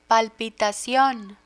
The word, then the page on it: palpitacion: Spanish